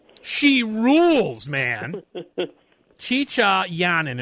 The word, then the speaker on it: Jeeja: It's spelled J-E-E-J-A-Y-A-N-I-N, but it's pronounced Chicha. So it's mm-hmm. – J-E-E-J-A, and then the last name is Y-A-I-N.